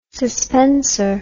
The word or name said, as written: suspenser